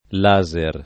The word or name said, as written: laser